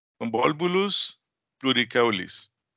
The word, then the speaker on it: Convolvulus pluricaulis: Convolvulus pluricaulis.